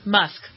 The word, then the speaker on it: Musk: Yeah, Musk.